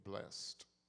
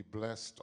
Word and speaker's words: blessed: The ED suffix on the end of the word bless means to be continuous, continually blessed, to be blessed on a daily basis, on an ongoing basis. So we don't pronounce the word blessed as in past tense, but blessed, because it means it happens every day.